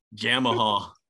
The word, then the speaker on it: Jamahal: You say Jamahal. Jamal. – Oh yeah. Jamahal. – I mean, that's how it's spelled for people that haven't seen it.